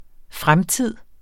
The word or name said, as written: fremtid